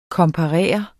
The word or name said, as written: komparere